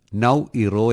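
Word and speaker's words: Naoero: അതെ, പക്ഷെ ഇനി മുതൽ നൗറു അല്ല, നൗ ഇറോ എന്നായി. – അതെന്നതാ ഈ പേര് മാറ്റിയേ? – ദേശീയ ഭാഷയുടെ ഉച്ചാരണം അനുസരിച്ചാണ് മാറ്റിയത്.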